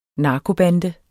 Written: narkobande

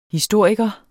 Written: HISTORIKERE

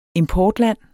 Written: importland

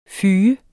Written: fyge

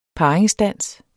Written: parringsdans

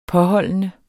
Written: påholdende

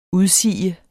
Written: udsige

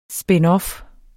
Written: spinoff

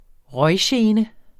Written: røggene

